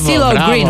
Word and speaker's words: CeeLo Green: Blige, DJ Goja, CeeLo Green, că m-a certat cum pronunțam CeeLo Green. CeeLo Green, uite că am reținut.